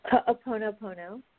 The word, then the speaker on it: Ho'oponopono: I'm sorry, how do you say that again? – Ho'oponopono.